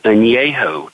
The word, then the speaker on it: Añejo: and it is Don Julio 1942 Añejo Tequila. – Añejo Tequila. Now, how would I spell it? – A-N-E-J-O.